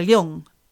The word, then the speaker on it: Leung: And Dr. Leung, you were right. Dr. Leung. – Okay. – Not long, there you go.